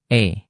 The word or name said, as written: e